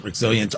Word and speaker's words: Rexillent: I think they pronounce it Rexilient, R-E-X-I-L-L-E-N-T.